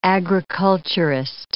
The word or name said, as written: agriculturist